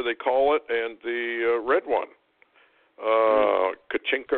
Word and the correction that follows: Kachina: or whatever they, you know, whatever the Hopis had to say. – Yeah. – I'm not sure Kachina. – Exactly how you pronounce it.